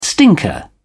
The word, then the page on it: stinker: British English